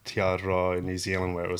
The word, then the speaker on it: Te Aroha: Te Aroha. – Te Aroha. Well, it's got a little tongue roll in it.